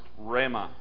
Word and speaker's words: Rhema: It's a word called, it's pronounced Rhema, which doesn't speak of the whole Bible.